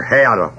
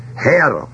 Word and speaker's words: Herr: Herr. That's right. Herr. H-E-R-R.